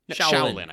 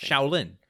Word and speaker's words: Shaolin: Shaolin? Am I saying that right? – Shaolin, I think. – Shaolin. – Yeah, take it from me. I would know.